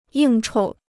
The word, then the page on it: 应酬: yìng chou